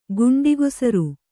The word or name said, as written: ಗಂಡಕೇಸರ